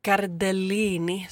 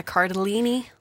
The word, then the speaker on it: Cardellini: Linda Cardellini, så kanske man inte säger. Linda Cardellini. – Ja, det är sagt någon sån italiensk-amerikansk uttal på den.